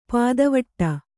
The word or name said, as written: ಪುದಿವೆಟು